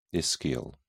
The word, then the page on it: ischial: British English